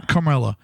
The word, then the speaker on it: Carmela: And even though Carmela is, how do you say her name? – Carmela.